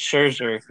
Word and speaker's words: Scherzer: They ejected one of the managers because he had Schwarzer – how do you say his name? – Max Scherzer. – Yeah. Scherzer, yeah. They had him checked like three times in like two innings.